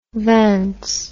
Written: vans

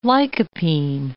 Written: lycopene

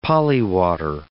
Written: polywater

